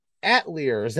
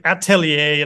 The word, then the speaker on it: atelier: Well, let's see, Fabian's Atelier, am I pronouncing that correctly? Is the it's pronounced like atelier. It's atelier? Like French or something.